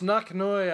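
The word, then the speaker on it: Nok-Noi: N-O-K dash. This motherfucker's got a dash in his name. N-O-K dash N-O-I. R-I-C-K-E-R. – That's right, it's Knocknoy, I would pronounce it. Knock Noy Ricker.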